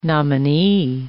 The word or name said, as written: nominee